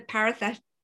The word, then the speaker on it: parasympathetic: And, you know, we know breathing stimulates the parasympathetic.